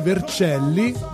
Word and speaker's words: Vercelli: Salutiamo tutti gli organizzatori dell'Università di Vercelli, detto bene, perché sì, sono tre praticamente città dell'Università del Piemonte Orientale, sono Novara, Vercelli e dalla regia Rossella e basta.